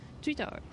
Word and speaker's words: Twitter: 5 syllable words Meanwhile, in English, Twitter, Twitter, Twitter, one syllable? Twitter. うん、すごいですよね。 Twitter、 Twitter。はい。で、ポイントは、たを言うときに、あの、小さいつを作るのが苦手だったら待ってください。 Twitter です。